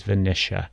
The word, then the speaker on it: Vanisha: My guest today is Vanisha.